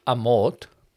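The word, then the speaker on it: Amorth: And what happens is Father Gabriel Amort is the Pope's exorcist.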